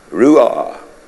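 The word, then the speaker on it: ruach: Hear this word, Spirit, R-U-A-C-H. I don't even know how to pronounce it. Who? Ruah. Good, that's good, that's good.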